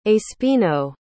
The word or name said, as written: espino